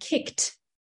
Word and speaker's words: kicked: So if you think about a word like kicked, the sound on the end is actually a T, but we don't spell it K-I-K-T. We spell it K-I-C-K-E-D.